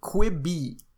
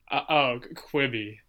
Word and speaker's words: Quibi: Quibi. – Tell me. – Quibi launched. – Quiver. – Quibi. – Quibi. – Q-U-I-B-I.